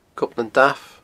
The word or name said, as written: Gob nan Damh